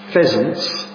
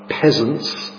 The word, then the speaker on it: pheasants: That's pheasants, not peasants. It's important to make sure you pronounce the PH properly.